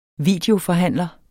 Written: videoforhandler

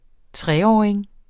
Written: treåring

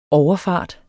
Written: overfart

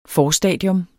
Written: forstadium

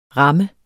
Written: ram

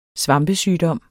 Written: svampesygdom

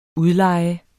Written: udleje

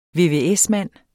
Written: vvs-mand

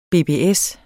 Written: BBS

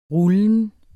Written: rullen

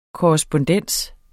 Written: korrespondens

